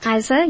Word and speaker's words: Eiser: Eiser, did I pronounce it correct? – Eiser, yes.